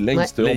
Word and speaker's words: Leinster: Alors Leinster, bien sûr, on dit Leinster. On prononce pas comme Gloucester, on dit Gloucester.